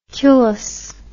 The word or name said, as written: culus